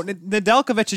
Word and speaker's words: Nedeljkovic: Starts Nemestadich. – Oh, Nedeljkovic is your starter. That one. – No, I thought... Okay, excuse me. – Nedeljkovic, that one.